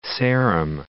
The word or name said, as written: Old Sarum